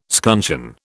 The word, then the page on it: scuncheon: British English